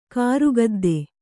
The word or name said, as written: ಕಾರುಗದ್ದೆ